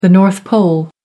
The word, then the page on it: north pole: American English